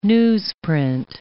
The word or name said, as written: newsprint